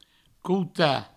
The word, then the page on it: cotar: couta